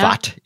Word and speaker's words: Pfaadt: Pfaadt is indeed how you pronounce that.